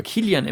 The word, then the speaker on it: Kylian: Und zwar mit den Wappen von Kylie Mbappé. – Kylian Mbappé, Mensch. – Also Kylian, du alter Fußballfan. – Einfach nur Englisch ausgesprochen.